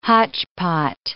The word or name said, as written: hotchpot